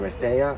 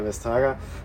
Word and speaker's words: Vestager: Es gab die Ankündigung der für die Digitalpolitik zuständigen Kommissarin, nochmal Vestager, Vesteja eigentlich ausgesprochen, aber wir nennen sie ja Vestager, ein sogenanntes Competition Tool, das ist so eine Art Marktbereinigungsinstrument, in das jetzt zu verabschiedende Gesetzgebungspaket zu integrieren.